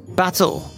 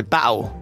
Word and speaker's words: battle: で今あの聞こえたと思うんですけども、あの battle, I say it with a pronounced T. Yeah. But some people say battle.